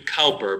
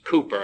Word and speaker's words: Cowper: I know this is the way most people pronounce his name, William Cowper, but actually it should be pronounced Cooper, and I've never forgotten that, so I can stand to be corrected, believe me.